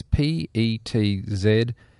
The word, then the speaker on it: Petzval: It's how do you pronounce it? Pretzel. It's PETZVAL.